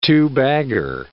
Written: two-bagger

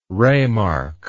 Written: ramark

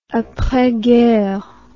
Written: apres guerre

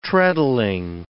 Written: treadling

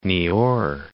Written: Niort

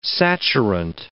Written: saturant

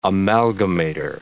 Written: amalgamator

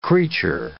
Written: creature

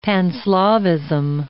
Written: Pan-Slavism

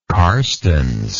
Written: Carstensz

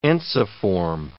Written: ensiform

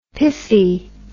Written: piffy

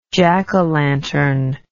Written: jackolantern

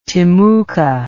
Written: timucu

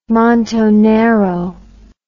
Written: montonero

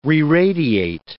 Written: reradiate

